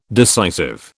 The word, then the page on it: decisive: British English